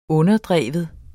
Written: underdrive

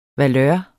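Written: valør

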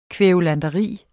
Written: kværulanteri

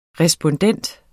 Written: respondent

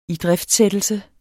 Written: idriftsættelse

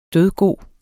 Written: dødgod

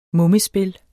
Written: mummespil

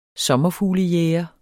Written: sommerfuglejæger

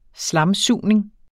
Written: slamsugning

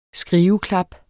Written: skriveklap

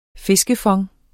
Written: fiskefond

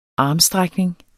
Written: armstrækning

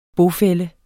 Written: bofælle

